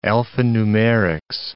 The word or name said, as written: alphanumerics